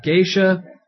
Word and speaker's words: geisha: I mean, our options were Memoirs of a Geisha... however you pronounce it. – Geisha.